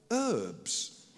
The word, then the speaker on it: herbs: In America, you call them herbs. There's an H. But you've had the 4th of July, so you continue with your mispronunciation.